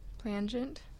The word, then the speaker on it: plangent: Plangent. – Just say folk. – Okay. Sounds like someone went copy paste. – Mr. Director of Library over there. – Plangent would be how you pronounce it.